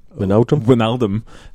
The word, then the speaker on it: Wijnaldum: Wijnaldum, thank you.